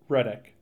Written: Reddick

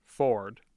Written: ford

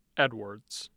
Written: edwards

